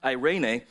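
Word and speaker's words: eirene (εἰρήνη): Well, it's interesting because the word peace in the New Testament is pronounced eirene, and, and it's really interesting because – the word picture of, of peace in the New Testament is having it all together.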